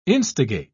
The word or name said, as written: instigate